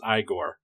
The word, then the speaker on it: Igor: I think actually it's pronounced Igor. – Yes, it is Igor, yes.